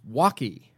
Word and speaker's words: WOKY: Which they pronounced Walkie. Get it? Because they were in Milwaukee.